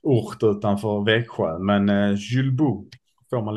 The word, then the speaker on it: Julbo: Ja, men jag har tränat här i, här i något år, men det är Chulbo, säger eh, fransmännen.